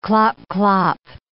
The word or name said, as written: clop-clop